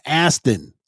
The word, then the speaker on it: Astin: Sean Austin is 51. He was Bob on Stranger Things. Mikey and the Goonies. – Sean Astin. – Yeah. – Sean Astin, not Austin.